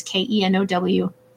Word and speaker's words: Keno: And then Keno is Kenow.